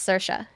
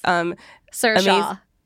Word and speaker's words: Saoirse: She's played by Saoirse Ronan. Did I pronounce it correctly? – Yes, Saoirse. – Okay. – Excellent. – I think I only know that from our coworker Hunter Harris's yes. Saoirse.